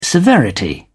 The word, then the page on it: severity: British English